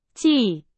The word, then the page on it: 季: Chinese